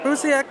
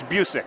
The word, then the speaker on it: Busiek: I just ran into Kurt Busiek. How you doing? – I'm doing all right. How about yourself? – I'm doing good. I didn't butcher your name, did I? – A little bit. It's it's Busiek.